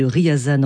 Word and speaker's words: Ryazan: Je rectifie donc la prononciation de la ville de Ryazan en Russie.